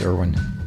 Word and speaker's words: Irwin: Irwin with an I, with two I's, in fact. I-R-W-I-N. I spell it only for the benefit of those who assume that Irwin is spelled with an E, as it often is, but not in this case. Muriel is spelled M-U-R-I-E-L.